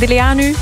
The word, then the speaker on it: Deleanu: Een hele goede morgen, Diana Deleanu uit Diest. Hallo. – Hallo, goedemorgen. – Hey, goedemorgen. Heb ik de achternaam juist uitgesproken, Deleanu? Was het goed? – Ja, fantastisch.